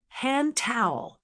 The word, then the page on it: hand towel: American English